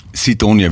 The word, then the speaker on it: Sidonia: ich bin auf Griechenlands Sidonia wird das ausgesprochen, okay.